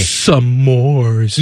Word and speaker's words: s'mores: You're putting another syllable in it. – It the same way you No, you're not. – S'mores. – S'mores? One syllable word.